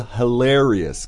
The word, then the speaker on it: hilarious: 对 hilarious.这儿大家的发音要注意你的重音 hilarious. – Yes, exactly, right?、Mm. Same as those other words. Stretch it out a bit. Hilarious.